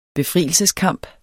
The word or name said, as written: befrielseskamp